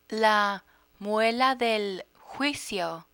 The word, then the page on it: muela del juicio: Spanish